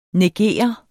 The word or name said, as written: neger